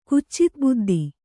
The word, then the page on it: ಕುಚಿತಬುದ್ಧಿ: Kannada